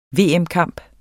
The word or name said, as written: VM-kamp